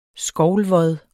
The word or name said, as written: skovlvod